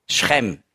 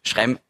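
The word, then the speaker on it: Schrem (שכם): Schrem, sagt man auf Hebräisch. Schrem. Sichem ist halt einfach die griechische Aussprache.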